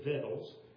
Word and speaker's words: victuals: Wherefore our elders and all the inhabitants of our country spake to us, saying, take victuals. And that's how you pronounce that. You don't pronounce the C. It is victuals.